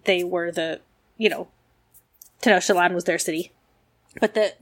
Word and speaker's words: Tenochtitlan: Apologize to all of the very nice people who have tried to teach me to pronounce that correctly. – Yes, I believe it's Tenochtitlan.